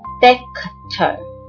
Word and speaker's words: Dexter: 在 Dexter 这个字上头，我们有点麻烦，发音是 Dexter，重音放在第一音节。